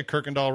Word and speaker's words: Kirkendall: I think Kershaw is, you know, okay, so it's 2-1-3-2-2 Kirkendall in spring. – Okay. – Did I say Kirkendall right? Because I know it's spelled No, that's right.